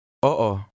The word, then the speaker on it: Oo: In Tagalog, when a word contains adjacent vowels, each vowel is pronounced separately with a slight pause between them. Try to read number 4. – Oo.